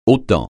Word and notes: autant: francais

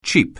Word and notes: cheap: anglais

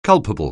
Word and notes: culpable: anglais

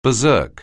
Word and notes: berserk: anglais

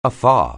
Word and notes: afar: anglais

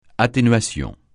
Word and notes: atténuation: francais

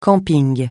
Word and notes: camping: francais

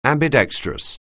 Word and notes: ambidextrous: anglais

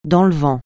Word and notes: dans le vent: francais

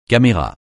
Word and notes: caméra: francais

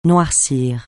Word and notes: noircir: francais

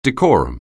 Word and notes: decorum: anglais

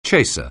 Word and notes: chaser: anglais

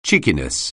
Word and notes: cheekiness: English

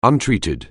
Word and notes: untreated: anglais

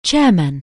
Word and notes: chairman: anglais

